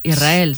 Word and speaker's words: Israel: Que suene la S, Israel, sí, sí, es difícil de pronunciar.